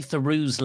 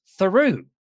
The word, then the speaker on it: Theroux: And apparently they have an argument on air about how to pronounce Theroux. Louis's family says it's Theroux, Louis Theroux, but Justin is Justin Theroux. – What? No, it's Theroux.